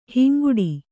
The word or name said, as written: ಹಿಂಗಟ್ಟು